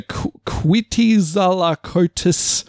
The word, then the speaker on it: Quetzalcoatlus: It's a Quetzalcoatlus.